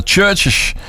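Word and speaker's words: CHVRCHES: Ja, dat waren de Churches. Zo spreek je het uit.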